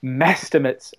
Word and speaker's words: mestimates: And I, and, and as an experiment, I tweeted for several months afterwards with the hashtag meh estimates or mestimates as it should be pronounced and people just it,